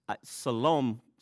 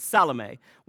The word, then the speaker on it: Salome: Everybody's asked how you pronounce that. That's the official Salome. Sounds nicer, but Salome went out and purchased burial spices so they could anoint Jesus' body.